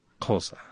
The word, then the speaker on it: Xhosa: Yeah, well, in fact, the majority of South Africans cannot pronounce these words as well, because it's Xhosa, right? – They belong only to that particular ethnic group, the Xhosa people. – Xhosa.